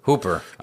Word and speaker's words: Hooper: I always get confused on how to pronounce his name. It's Toby Hopper or Toby Hooper? – Hooper.